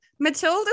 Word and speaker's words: Matilde: Yeah, so Matilde is how you pronounce it if you're in most of Europe, and my mom's from Holland.